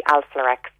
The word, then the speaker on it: Alflorex: The first thing is I would definitely try the Alflarex probiotic that's been developed here in Cork. That can help a lot with people who suffer from diarrhoea. It's spelled A-L-F-L-O-R-E-X, and you'll get it not just in health shops, but in pharmacies.